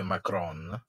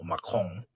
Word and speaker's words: Macron: Che ancora oggi, se tu vedi quello che dice Macron, o Macron, no? Eh, se lo vuoi pronunciare bene... – Eh, quando si parla italiano, onestamente, no, eh, Macron.